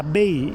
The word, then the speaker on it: Bey: Si pronuncia Bey, il Bey, sì.